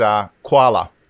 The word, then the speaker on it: Kwala: Some people might think that the app is called Kwala, which of course is always associated with Australia, but it's you actually just enlightened me and said, no, it's two syllables. It's Kwala.